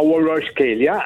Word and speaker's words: Kvaratskhelia: Due mesi e mezzo, tre mesi fa, e si chiama Kvaratskhelia, allora non sapevo che si pronunciava così, e veramente ogni volta mi stupisce.